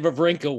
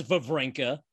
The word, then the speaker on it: Wawrinka: For years, it was Wawrinka. For 1 year, it was actually Wawrinka. And then when he came to the U.S. Open, he went back to Wawrinka.